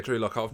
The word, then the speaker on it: Lock: Yeah, Drew Lock.